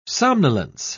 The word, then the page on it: somnolence: British English